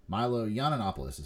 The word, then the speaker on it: Yiannopoulos: Milo Yiannopoulos is how you say his name.